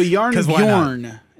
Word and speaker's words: Jarnbjorn: The reason I couldn't spell it is because it is spelled Jarnbjorn. So Yarnbjorn is probably how it's supposed to be pronounced.